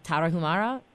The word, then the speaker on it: Tarahumara: And in your book, you describe finding the, I'm not going to pronounce it as well as you, Tarahumara? – Very good, yeah.